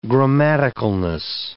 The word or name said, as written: grammaticalness